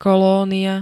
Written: kolónia

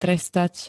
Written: trestať

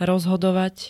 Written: rozhodovať

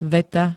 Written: veta

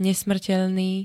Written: nesmrteľný

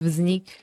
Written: vznik

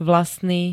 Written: vlastný

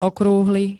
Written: okrúhly

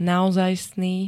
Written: naozajstný

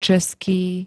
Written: český

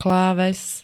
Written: kláves

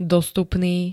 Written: dostupný

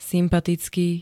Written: sympatický

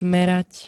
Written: merať

